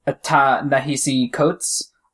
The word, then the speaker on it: Ta-Nehisi Coates: Um, so it's really interesting because it's written by, um, Ta-Nehisi Coates. Um, and I hope I pronounced that oh. right.